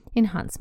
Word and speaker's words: enhance: Кстати, в американском варианте оно произносится enhance, enhance, enhance, enhance. Помните, это то же самое, что fast and fast. Dance and dance. Британцы произносят глубокий звук а, а американцы произносят звук лягушка, называется в фонетике э. э.